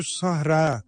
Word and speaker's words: Sahra: Sevgili kardeşlerim, bu kelimeyi yanlış telaffuz ediyorlar. Sahra, Kubbetü Sahra diyor. Sahra değil, Sahra. Ra çekmiyor. Ra'nın önünde yuvarlak ta var.